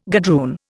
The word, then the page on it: gadroon: Indian